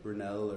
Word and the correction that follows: Buñuel: Buñuel? – Okay, thank you.